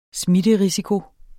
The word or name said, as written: smitterisiko